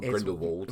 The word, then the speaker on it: Grindelwald: I realised I was saying it wrong about 10 minutes into the film because they make it very, very clear that it's Vold and not Grindelwald. – Vold with a V.